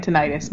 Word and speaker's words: tinnitus: Which is apparently pronounced tinnitus in England. – No, in West Virginia, according to tinnitus. Dr. Cindy McElroy, it's tinnitus. What? Apparently, everybody who has it pronounces it tinnitus, but doctors pronounce it tinnitus.